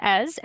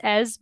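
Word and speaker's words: Èze: And same with Ez. Ez was, if you do choose to go to Ez when you're in the area, it's spelled Eze. I think there's an accent in there somewhere, but it's like I said before, it's like a medieval town.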